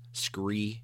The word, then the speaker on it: SKRE: However, we have a really cool podcast today with another direct-to-consumer camo company, and that is SKRE, and that's pronounced Scree Camo, Scree Gear.